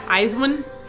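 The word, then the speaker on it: IZ*ONE: Eyes One? Am I saying that right? – Yeah.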